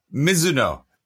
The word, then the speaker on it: Mizuno: Yeah, Mizuno. That's how you actually, that's like the technical proper pronunciation, isn't it?